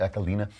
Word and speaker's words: Aquilina: And at the end of this, Judge Rose Marie Aquilina, I believe it's pronounced. Is that the right pronunciation? Aquilina.